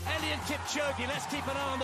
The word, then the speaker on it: Eliud Kipchoge: Bueno, Eliud Kipchoge, que así lo pronuncian de bien, no como nosotros que decimos muy Kipchoge bien. O lo que nos pase por la cabeza, ha corrido más que una liebre.